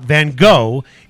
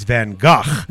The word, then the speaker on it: Van Gogh: I just wanted to say Van Gogh because it's not Van Gogh, it's Van Gogh.